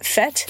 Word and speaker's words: Fête: How do you say that? Fête? – Fête. – Thank you.